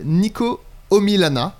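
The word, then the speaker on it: Niko Omilana: C'est une vidéo YouTube d'un YouTuber que j'ai découvert il n'y a pas très longtemps qui s'appelle Nico Omilana. N-I-K-O, Omilana, comme ça se prononce.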